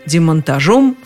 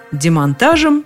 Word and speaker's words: демонтаж: Он предлагает нам вариант демонтажа, демонтажу, демонтажом в качестве основного. А по поводу демонтажа демонтажем сообщает, что такое ударение устаревающее.